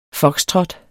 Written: foxtrot

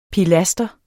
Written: pilaster

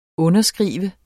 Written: underskrive

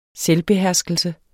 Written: selvbeherskelse

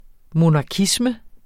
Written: monarkisme

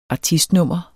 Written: ARTISTNUMMER

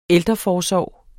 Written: ældreforsorg

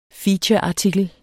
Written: featureartikel

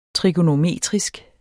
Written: trigonometrisk

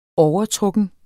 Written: overtrukken

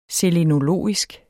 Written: selenologisk

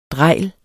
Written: DREJLETS

